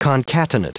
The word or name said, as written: concatenate